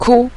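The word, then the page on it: ku: tone 5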